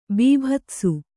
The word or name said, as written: ಬೀಭತ್ಸ